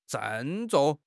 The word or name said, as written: 郑重